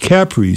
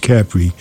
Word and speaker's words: Capri: She said, no, it's pronounced Capri. So ever since then, whenever I see the word, I pronounce it the way the Italians do, which is Capri.